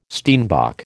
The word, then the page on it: steenbok: American English